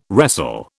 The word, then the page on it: wrestle: British English